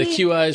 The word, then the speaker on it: Qi: I believe that's Qi. Isn't it Qi certified wire? – Is it Qi? – Yeah, the QI is.